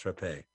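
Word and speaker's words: Rappe: By the way, her name was Rapé. It's pronounced Rapé.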